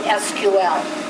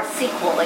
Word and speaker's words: SQL: So behind the scenes, Access makes these into SQL or SQL. It can be pronounced either way. It's about a 50-50 whether people like SQL or SQL.